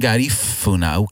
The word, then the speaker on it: Garifuna: You mentioned Garifuna.